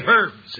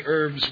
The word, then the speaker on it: herbs: And I understand now it's more and more proper to pronounce the H and to say herbs. It still sounds funny on my antiquarian ear, so I will continue to say herbs with a silent H.